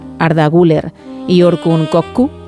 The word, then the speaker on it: Güler: Creo que Arda es Arda Güler.